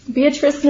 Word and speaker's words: Beatrice: Is it Beatrice? – Yeah. – Beatrice, Nebraska, yeah.